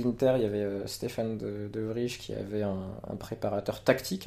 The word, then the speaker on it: De Vrij: Je sollicite juste Christophe pour la correction de la prononciation, s'il te plaît, Christophe, du défenseur. – Ah, De Vrij. – De Vrij. – De Vrij, pardon.